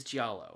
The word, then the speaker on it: giallo: But if you go giallo, you're correct. – Ah, dang. – It is, okay. It is giallo.